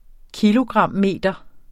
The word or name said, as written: kilogrammeter